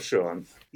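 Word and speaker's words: Schuon: So why don't you pronounce Sean properly? – You've got it right. – What about the first name, though? – Ah, Fritjof. So Fritjof Sean.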